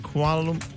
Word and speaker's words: Kuala Lumpur: Is it Kuala Lumpur? – Yeah, you said it right.